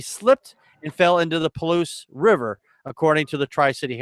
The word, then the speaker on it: Palouse: Yes, the Palouse River.